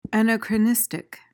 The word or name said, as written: anachronistic